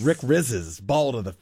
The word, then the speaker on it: Rizzs: Rizzes? R-I-Z-Z-S. How would you say it? – R-I-Z-Z-S? – Yeah. – Rizzes. – Would you say Rizzes? – Rizzes. – Rick Rizzes. – Yeah, Rizzes.